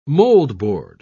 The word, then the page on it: moldboard: British English